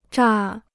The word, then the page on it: 榨: zhà